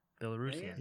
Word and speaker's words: Belarusian: How do you pronounce somebody from Belarus? – Belarusian? All right. Well, I'm going to type that in. Belarusian. – You're right.